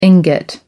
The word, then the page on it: ingot: American English